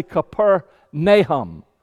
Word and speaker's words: Capernaum: And the name, the guide will tell you, the name Capernaum is actually Capernaum. Capernaum. That's how they say it over there, Capernaum.